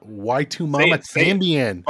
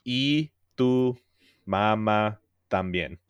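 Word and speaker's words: Y tu mamá también: How do I say this properly, please? – Y tu mama también. – Y tu mama también. – So no, like the letter E. So E.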